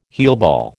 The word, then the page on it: heelball: American English